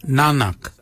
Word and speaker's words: nangag: So for neck we say nanak, nanak. Nanak is spelled N-A-N-G-A-G, Nanak. Nanak is your neck. Chin, we say yet. Yet for chin. Yet is spelled Y-E-D, yet.